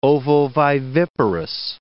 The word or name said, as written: ovoviviparous